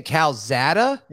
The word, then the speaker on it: Calzada: Say his name again. What are you saying? – Calzada. – Why are you saying it like that? – Well, it's how it's pronounced. You don't call him Zach. – You're saying Calzada? – Yeah, Calzada. That's what I'm going. It sounds cooler than Calzada. I'm going with Calzada.